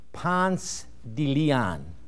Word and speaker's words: Ponce de León: For if you do use that, you'll be immediately identified as an outsider and definitely not a local, for the locals pronounce that town Ponce de León.